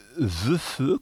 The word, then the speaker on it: VEF: Quand on dit VEFE, comment est-ce qu'on dit ? Ça s'écrit VEFE. – Oui, alors ça se prononce VEF.